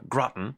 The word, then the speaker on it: Groton: My father had retired from submarine service that year, 21 years stationed in uh, Groton, rhymes with rotten, That's how I know it's that.